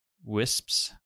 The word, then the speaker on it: Wisps: Do you pronounce the second S when you say that? – Wisps. Yeah.